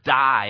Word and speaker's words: dei: And as I looked up that phrase, I must visit Rome, the Greek word for must there is the word die, and it's spelled D-E-I, though, and it's translated into being absolutely necessary.